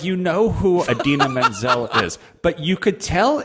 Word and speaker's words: Idina Menzel: mispronounced Idina Menzel and said the wickedly talented Adele Dazeem.